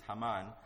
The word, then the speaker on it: Haman: But I would like to point out that the main characters are actually just four: King Xerxes, Mordecai, Haman, or some Hebrew would pronounce this as Haman, and Esther.